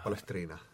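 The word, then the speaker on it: Palestrina: vastaja on praegu rumal, et ei oska vastata sellele täpselt, aga kuidas seda, kuidas see õige hääldus on, Palestriina? – Palestriina.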